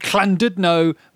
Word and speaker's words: Llandudno: It's the double L in Wales. It's like a cl, isn't it? – It's quite phlegmy. – Yeah. His solicitor, Jamie Gammon, told Llandudno Magistrates Court he had heard...